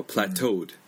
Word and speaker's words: plateaued: 네, 그래서 plateau 그냥 그 발가락 있잖아요, 토. 네. 그 발음으로 해도 돼요, plateaued. 그냥 토. 네, 그냥 오처럼 이렇게 하셔도 되고.